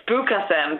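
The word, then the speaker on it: spookasem: There's one you wrote down about the Afrikaans word for candy floss, and it's spook awesome. Oh, yeah, lovely, isn't it? – How would you pronounce it? – I would say it's spook awesome, but you might have a better pronunciation, actually.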